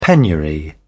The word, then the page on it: penury: British English